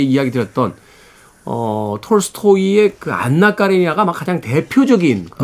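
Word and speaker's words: Anna: 토토의 음악 중에서 안나 듣습니다. 토토의 안나 듣고 왔습니다. 원래는 이제 애나 이렇게 발음하는데 예, 오늘은 주인공이 안나니까 그렇죠, 그렇죠. 예, 안나로 읽어드렸습니다.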